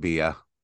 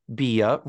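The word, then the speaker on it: Beah: It's written B-E-A-H. I don't think it's pronounced Bea. I think it's just B. Richards. – I think the H makes it Bea.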